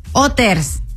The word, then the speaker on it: Hooters: Se dice Hooters. – Hoy no más. ¿No? – Bueno, pues sí, ¿dónde? Porque la H es muda o test.